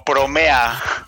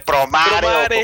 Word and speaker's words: Promare: Pero si lo lees en español, pues dice promare, pero pues se pronuncia en Japón Promare. Promare.